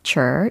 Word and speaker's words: capture: 참고로 이 캡처라는 것은 어, 캡 capture 이라는 정확한 발음일 거고, 잡다, 포획하다 라는 뜻입니다.